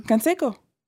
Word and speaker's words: Canseco: Canseco.